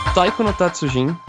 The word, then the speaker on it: Taiko no Tatsujin: Taiko no Tatsujin, eu falei certo, João? – Falou certo, tá de parabéns.